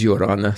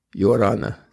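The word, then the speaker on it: Ia ora na: Some people say it's easy to remember how to pronounce it when you look at a judge in the court and you say, Your Honor. That's a little bit like it. Yorana. Yorana. With the Spanish R.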